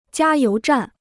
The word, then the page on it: 加油站: jiā yóu zhàn